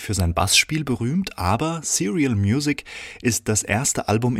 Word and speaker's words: Serial: William Parker und Ellen Christie mit Baseball, einem Track von dem neuen Album Serial Music, ausgesprochen, aber nicht geschrieben wie die serielle Musik, sondern wie die Cerealien, also das Müsli, das, was man als Kind jeden Morgen in sich reinschaufelt, um Kraft für den Tag zu haben.